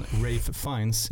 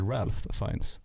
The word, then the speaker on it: Ralph Fiennes: Men Rafe Fiennes, jag tror jättemånga säger väl Ralph Fiennes till honom.